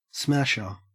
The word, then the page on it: smasher: Australian English